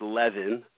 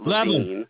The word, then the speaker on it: Levin: So my last name is pronounced Levin, not Levine.